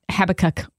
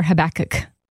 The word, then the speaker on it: Habakkuk: If you're not familiar with it, let me just give you kind of the skinny on Habakkuk or Habakkuk. How would you say it? – Oh, let's see. Habakkuk. – Habakkuk? – Habakkuk. – Habakkuk. Yeah, I mean it can go either way. Tomato, yeah. tomato, tomato, right? – Either either one works.